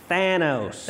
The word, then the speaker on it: Thanos: Someone pointed out, apparently I pronounce his name wrong because I say Thanos. – And I How is it pronounced? – It's Thanos.